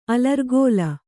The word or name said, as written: ಅಲರ್ಗೋಲ